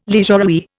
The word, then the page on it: leisurewear: Indian